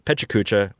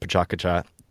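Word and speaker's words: PechaKucha: but Pechacucha, which I hear is pronounced Pachacucha.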